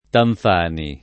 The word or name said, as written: Tanfani